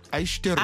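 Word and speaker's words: Aishiteru (愛してる): Comment Aishiteru. – Aishiteru. – Aishiteru. – Non, not aishiteru. Aishiteru. Aishiteru. – Aishiteru. – Aishiteru. Aishiteru. – Ok. Aishiteru. – Yeah. Quatre syllabes. Aishiteru.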